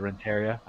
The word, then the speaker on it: Renteria: I don't know why I said Renteria. I think I was reading it phonetically, and I forgot that I have some Hispanic roots in me. Renteria. – Roll those R's, man.